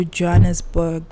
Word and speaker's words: Johannesburg: So obviously, after the wedding, they still took them to Johannesburg.